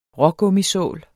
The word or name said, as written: rågummisål